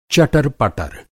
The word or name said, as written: चटरपटर